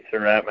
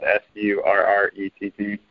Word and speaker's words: Surrett: My email address is Surrett. My last name is spelled Surrett at clinton.edu.